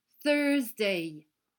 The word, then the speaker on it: Thursday: Ça se prononce Today is Thursday, en mettant la langue entre les dents pour faire le son the.